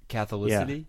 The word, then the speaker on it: Catholicity: Virtual Catholicity. Did I say that right? Sometimes words don't sound correct when they leave my mouth. – Catholicity? Yeah, that's right.